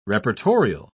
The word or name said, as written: reportorial